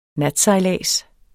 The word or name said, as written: natsejlads